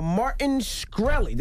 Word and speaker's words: Martin Shkreli: Goes by the name of Martin Shkreli.